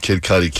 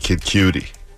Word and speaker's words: Kid Cudi: That was at the time you called Kid Cudi, Kid Cutie.